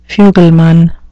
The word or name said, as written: fugleman